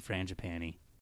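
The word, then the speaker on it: frangipane: So it's pastry and then a layer of jam, and then frangipani, and then icing. – How do you say frangipan? Do you do the E at the end? – I mean, I don't know if that's right, but I do.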